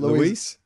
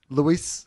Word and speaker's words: Luis: the big guys not only had in their lineup number one song right around the world, Luis Fonsi. Uh, did I put it Luis Fonsi? – I, I don't Louis, think Luis. Luis. Luis, Luis Fonsi.